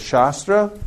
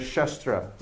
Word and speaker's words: shastra: There's shastra with a long A, which means the scripture. And there's shastra, which means a weapon like a sword.